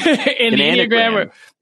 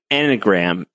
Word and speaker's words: Enneagram: I was pronouncing Enneagram. – Enia. – Enia. – Like Virginia. Virginia-gram.